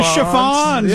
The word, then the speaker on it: Chiffons: She's So Fine, the Chiffins. – How about the chiffons, dude? The Chiffins. The Chiffins.